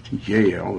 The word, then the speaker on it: Jael: And even Yael, it's spelled J-A-E-L in fourth and fifth chapter of Judges, where she was the one, Yael was the one that defeated Sisera.